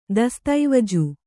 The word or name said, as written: ದಸ್ತಾವೇಜು